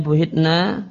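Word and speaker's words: buhitna (بهتنا): Ya, tentang fa buhitna bil bina lil majhun, ya, bacanya buhitna, maknanya ukhitna bartatan, kami tercengang tiba-tiba, wa intibatatuna al hayratu, dan kami tertimpa al hayrah, kebingungan.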